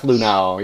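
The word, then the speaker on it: Luhnow: Because apparently, if you read both of the statements from Hinch and Lunau, that, you know, am I saying that right? Lunau? – Yep, Jeff Lunau, yep.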